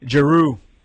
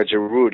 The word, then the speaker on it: Giroud: Giroud. – Yeah, Giroud.